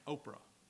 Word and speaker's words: Oprah: It's Ophrah, but her mama dropped that P and it just pronounced Oprah, but it's made her a lot of money.